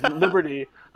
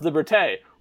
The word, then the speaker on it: liberté: says liberty, uh, and then and then the, the joke is it's pronounced liberté well, we pulled that audio and we reused it over and over again because people kept screwing it up.